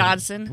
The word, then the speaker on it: Dodson: Is it Dobson or Dodson? – Dodson. – With a D, Dodson. All right. – D as in dog.